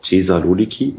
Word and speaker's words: Thessaloniki: Das ist Südserbien, das ist das ganze Staatsgebiet von Albanien, das ganze Staatsgebiet von Mazedonien, komplett Nordgriechenland mit Thessaloniki, wie spricht man das aus? Thessaloniki? Ja, mhm.